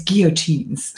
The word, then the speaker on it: guillotines: So you were just talking about the Nazis, and terrible, because they also, to save on whatever, they had guillotines for peasants that say it wrong. I'm a peasant. It's guillotines, French. They had guillotines in the town hall basements, and they used to chop the heads off these poor people.